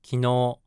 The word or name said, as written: kinou